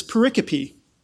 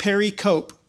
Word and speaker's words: pericope: If you want the fancy seminary term, it's pericope. It's, if you write it down, it looks like pericope, because I pronounced it that way until I heard somebody say pericope.